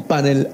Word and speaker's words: panel: Quisiera preguntarle si se dice panel con acento en la A o panel con acento en la E, profesor. – Sí, la palabra está mal pronunciada en español. En inglés decimos panel, en inglés es panel, pero en español es panel, el panel, ese es lo correcto en español.